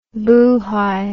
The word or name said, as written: boohai